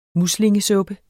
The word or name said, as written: muslingesuppe